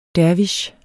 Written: dervish

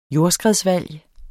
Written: jordskredsvalg

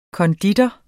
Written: konditor

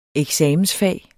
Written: eksamensfag

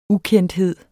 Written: ukendthed

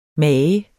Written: mage

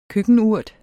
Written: køkkenurt

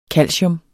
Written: KALCIUM

